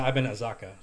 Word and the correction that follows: Akaza: Sorry about that. – It's Akaza.